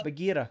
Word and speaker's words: Bagheera: To him providing the voice in the Jungle Book of Bagheera. – Bagheera? – Yep. – Really? Say it properly, please. – How is it pronounced? Bagheera? – Bagheera. – Bagheera. All right. That's just my bad.